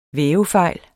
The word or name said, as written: vævefejl